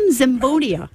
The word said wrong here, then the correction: Zenobia: Zenobia. – See? I can't even pronounce like, so, it. – It's okay. I've been called Zamboni and everything Zenobia.